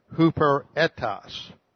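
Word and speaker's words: huperetos (ὑπηρέτης): It is the Greek word huperetos. Looks like this. H-U-P-E, this is a long E, huperetos.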